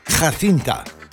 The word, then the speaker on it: Jacinta: Als ik het op zijn Spaans ga uitspreken, moet de J, de God daar moet een J zijn. Er staat een J, maar dan moet een G zijn. Jacinta. El Paradero Caliente.